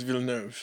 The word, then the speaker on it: Villeneuve: I never pronounce his name right. – Denis Villeneuve. – Villeneuve, okay, for Dune.